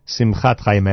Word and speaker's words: Simchat Chaim: Yehudis comments that Lenny Solomon's brand new album is entitled Simchat Chaim. Simchat Chaim. I may have mispronounced that earlier.